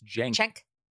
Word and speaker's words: Cenk: I think it's Cenk. – Cenk. Cenk. Okay.